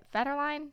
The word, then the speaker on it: Federline: But when Britney's ex, Kevin Federline, is that how you say it? Federline? – If I recall, yes.